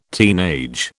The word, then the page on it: teenage: British English